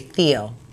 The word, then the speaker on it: Thiel: I would say Thiel because it's T-H-I-E-L.